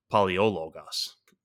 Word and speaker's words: Palaiologos: Palaiologos. That's a bit of a mouthful. – Yeah, no, that's how you say it.